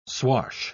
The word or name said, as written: swash